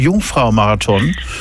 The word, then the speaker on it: Jungfrau-marathon: Wat is de Jungfrau-marathon? – Ja, dat spreek je heel goed uit.